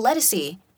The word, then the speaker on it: Ledisi: And Ladisi, do you know who Ladisi is? – Mm-mm. – She's like a neo-soul. – Oh, Ledisi. – Oh, is that how you say it?